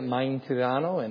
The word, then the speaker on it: Mintrano: If you guys have read our prayer letters, that's how you say it, Mintrano.